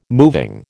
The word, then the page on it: moving: American English